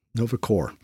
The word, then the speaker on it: Nova Corps: Why did I pronounce the S? Nova Corps.